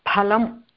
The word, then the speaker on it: फलम्: फलं तत्र सर्वदा फलम् इति एव उच्चारणं करणीयः फलं तथा एव कृष्णफलकं फ संस्कृतभाषायां फ अस्ति न तु